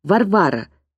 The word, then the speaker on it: Варвара: По-русски это имя произносится Варвара с ударением на второе «а».